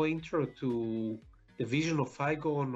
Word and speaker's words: Iagon: With us today, we have the COO of Aegon. Did I say that right? – That's right. Well, we pronounce it Aegon. I-A-G-O-N.